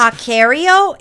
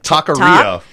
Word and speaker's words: Taqueria: Is it Taquerio? Is it Taquerito? Like Taqueria. Taqueria Lupita's.